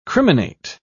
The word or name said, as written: criminate